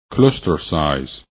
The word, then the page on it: clusters: english